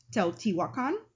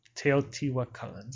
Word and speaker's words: Teotihuacan: Oh, so one of the ones that we've only played twice and we got it as a gift for Christmas is Teotihuacan. – Teotihuacan.